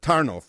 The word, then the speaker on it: Tarnów: And so there's a town called Tarnów, which is just about an hour or so out of Krakow. And if you notice, I'm saying it in the Polish pronunciation.